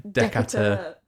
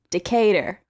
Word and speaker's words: Decatur: And don't say Decatur. Decatur. – You've got to say it like you're American. – Decatur.